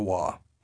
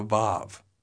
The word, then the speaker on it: Vav: One form of pronunciation is similar to the English V sound, called the Vav. The other form of pronunciation is similar to the English W sound, called the Wa.